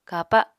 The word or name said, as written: kapa